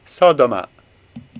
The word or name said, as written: Sodoma